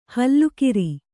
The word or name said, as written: ಹುಲ್ಲೆಕರು